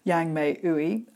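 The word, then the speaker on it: Yang-Mai Ooi: You can find it by searching for my name on Spotify, Yang Mei Ui, and I'll spell that for you, Yang Mei, Yang hyphen Mai, Ui, Ooi.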